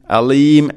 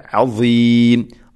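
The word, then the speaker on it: അസീം (عظيم): പ്രത്യേകം ശ്രദ്ധിക്കേണ്ടത് അസീം, അലീം, അലീം അല്ല, അസീം. ദ, ദി, ദു, അസ്, ഈ ഉച്ചാരണം അസീം.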